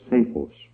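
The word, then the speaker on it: psephos: It is the word saphos. Phonetically, it's P-S-A-P-H-O-S.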